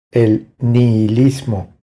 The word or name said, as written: El nihilismo